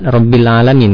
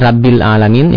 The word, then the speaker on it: rabbil alamin: Ya Ustadz yang saya cintai karena Allah, saya ingin bertanya, di kampung saya di Sumatera itu kalau membaca ro bukan ro tapi ra, seperti rabbal alamin, tidak robbil robbal, robbil alamin ya. E, seperti rabbil alamin ya, tidak robbil alamin.